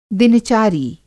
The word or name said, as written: दिनचारी